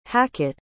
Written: hacket

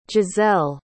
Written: gisele

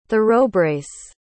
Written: thoroughbrace

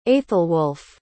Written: aethelwulf

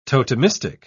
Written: totemistic

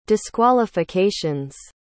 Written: disqualifications

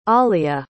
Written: Olea

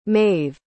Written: Mave

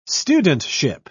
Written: studentship

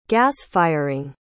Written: gasfiring

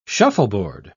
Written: shuffleboard

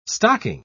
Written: stocking

